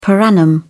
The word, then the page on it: per annum: British English